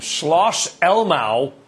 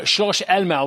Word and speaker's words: Schloss Elmau: And by the way, you didn't butcher that name. Schloss Elmau was a very, very good pronunciation, Brian, as well. So 10 out of 10 on that one.